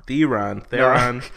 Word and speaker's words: Theron: Theron. Even before we started recording, I made you say it that way.